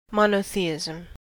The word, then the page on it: monotheism: American English